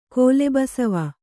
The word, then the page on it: ಕೋಲೆಬಸವ: kōlebasava